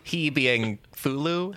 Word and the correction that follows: Cthulhu: How do you say it? That's who I was thinking of. The tentacle monster on the throne. Yeah, you know. – Cthulhu. – Yeah. There we go.